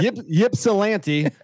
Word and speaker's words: Ypsilanti: How do I pronounce that? – Ypsilanti.